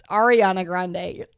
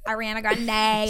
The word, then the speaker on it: Ariana Grande: It's Ariana Grande. Ariana Grande. – Oh. – That's how you say it. – Ariana Grande.